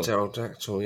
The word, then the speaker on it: pterodactyl: But, like, I still get tripped up by the P in dinosaur names. – Yeah. – Because like, pterodactyl's not pterodactyl. – Oh, yeah, pterodactyl, yeah. – Silent P, isn't it, with dinosaurs.